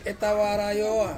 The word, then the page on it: tawara oa: Ansus